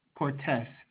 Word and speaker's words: Cortes: It's Chris, C-H-R-I-S. – And your last name? – Cortes, C-O-R-T-E-S, but it might be important to note that it was purchased by Patrick Scheel.